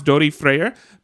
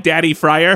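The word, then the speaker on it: Daði Freyr: This band is called, I believe it's pronounced Dori Freyer, but it looks like Daddy Fryer. Mm-hmm. So good luck trying to find it.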